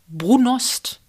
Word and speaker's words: Brunost: Brunost.